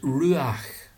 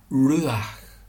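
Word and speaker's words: ruach (רוּחַ): The Hebrew word is ruach, and you cannot say it properly without breathing out, ruach.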